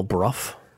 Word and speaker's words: Bruff: Adele Bruff. – Uh-huh. Oh. – She says it's like rough. It's rough being bruff.